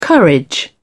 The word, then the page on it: courage: British English